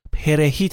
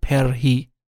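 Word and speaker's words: perejil: شهروندان دومینیکن این کلمه رو پرهی تلفظ کردن در صورتی که مردم هایتی ر رو ساکن تلفظ می میگفتن پرهی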